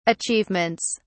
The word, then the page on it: achievements: British English